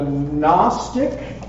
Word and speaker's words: Gnostic: Number two is going to be what I call the Gnostic, you don't pronounce the G in this word, the Gnostic denial of Christ's humanity.